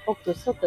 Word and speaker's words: OXXO: OXXO と書いて、オクソと読みますが、こちらのコンビニに、あ、うるさい。